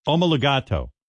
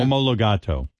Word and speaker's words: Omologato: Gran Turismo. Am I saying that right, guys? Right. Gran Turismo Omologato. – Wow. – Really? That's a new one for me. – Yeah. Omologato. – It also stands for get topless or else. – Howard, that pronunciation is correct.